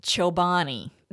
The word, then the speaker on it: Chobani: We have a brand of yogurt here called Chobani. The name of the yogurt is spelled C-H-O-B-A-N-I, Chobani.